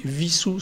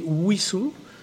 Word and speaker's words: Wissous: Et en tout cas, ça indique une seule chose, c'est que normalement, si on est fidèle à l'histoire de, la commune, on ne devrait pas prononcer visous, c'est-à-dire avec le W à l'allemande, mais le, on, devrait prononcer Wissou avec le W à l'anglaise.